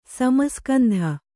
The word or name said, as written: ಸಮಸ್ಕಂಧ